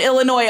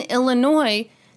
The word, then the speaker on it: Illinois: There's been a large number of people who have reached out to us and said that, yes, in fact, people do pronounce the S on Illinois all the time.